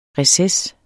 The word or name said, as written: RECESSERNES